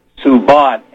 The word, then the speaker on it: Subodh: 2201-theism and atheism, comma, reason and faith, / come search with me, - now if I pronounce this name right, I'll spell it, Subhat Pandit, Subhat, S-U-B-O-D-H, Pandit, P-A-N-D-I-E.